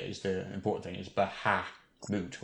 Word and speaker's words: Bahamut: The original pronunciation is the moot version, Bahamut, although the mat, Bahamat, is more common these days.